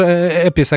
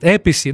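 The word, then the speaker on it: Appice: И он там произносит такой, знаете, целый спич, что так как он итальянец, то эту фамилию можно произносить Апичи, но так как он живет в Америке, то все говорят Эпис, а сам он себя называет Эписи, ну вот что-то такое.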